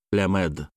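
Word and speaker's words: Лямед (למד): Имя бога 12-го аркана — Ламед, Лямед. «Л» здесь мягкое, пишется обычно «Ламед», но читается как «Лямед».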